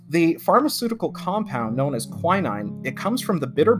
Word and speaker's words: quinine: So again, it's pronounced quinine. I had to actually look that up, because a lot of times when you see it spelled, you're like, is that kinine?